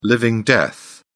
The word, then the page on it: living death: British English